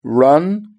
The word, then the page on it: run: British English